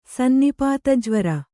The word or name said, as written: ಸನ್ನಿಪಾತಜ್ವರ